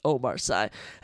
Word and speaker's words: Omar Sy: I was going to say Sy, it's just Omar Sy, as he takes on the gorilla, Brenson Ribeiro. Omar Sy, 11-1, coming off his first professional defeat.